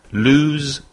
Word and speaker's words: lose: Lose, lost, lost. – The vowel sound is oo. Lose.